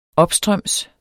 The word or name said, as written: opstrøms